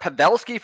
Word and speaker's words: Pavelski: Yeah, I will fix you on the pronunciations here, always. But hey, no problem, buddy. We got Pavelski for Dallas. – Pavelski, thank you.